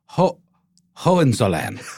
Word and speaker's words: Hohenzollern: And in 1660, a guy named Frederick William of the House of, how do we say it? – Oh, gosh. Hohenzollern. – Hohenzollern. Yes.